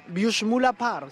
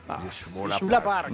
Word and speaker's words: Büschmüller Park: Voy a ver si lo pronunció bien porque es que tiene nombre alemán. Büschmüller Park. – Sí, está perfecto. – Esto es aquí en Jersey. – Büschmüller Park.